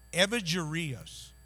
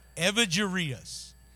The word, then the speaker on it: Evagrius: It's Evagrius. Spell it however you want to. Evagrius.